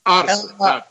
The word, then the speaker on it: Арсе: Луис Арсе, правильно? – Арсе, так. Ударение Арсе. На первой букве.